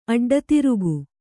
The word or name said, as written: ಅಡ್ಡತಿರುಗು